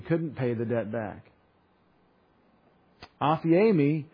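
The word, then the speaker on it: aphiemi (ἀφίημι): It is aphiemi. A-P-H-I, long E-M-I, aphiemi.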